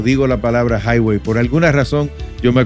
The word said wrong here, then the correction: Huawei: No, Huawei. – ¿Tú ves que yo no. – Huawei. – Oye, de la forma de cómo se escribe a cómo se pronuncia es muy diferente. – Sí, sí. Claro. – H-U-A.